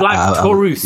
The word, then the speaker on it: Black Taurus: Have I said that right? I thought it was just Black Taurus, but yeah, Black Taurus. – Yeah, you got it right. – I got it right. Awesome.